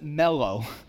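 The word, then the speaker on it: mellow (μέλλω): It actually literally in the Greek, you would pronounce it mellow, which I just thought was funny.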